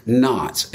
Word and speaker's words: knot: 5 climbing knots. And please know, you see, I put that in quotes. I want you to know I know how to spell knot. I know there's a silent K in there.